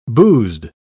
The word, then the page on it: boozed: British English